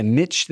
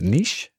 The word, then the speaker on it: niche: Is that like a niche thing that's like, how do you say that? Niche? – Niche? Ni- well, both of them are correct.